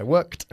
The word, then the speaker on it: worked: If I worked less, yeah, if I worked. La ED se pronuncia casi como una T.